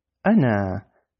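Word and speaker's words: ana (أنا): Esto se pronuncia ana, ana, lo que significa en el español yo, yo, ana, primera persona, ¿no?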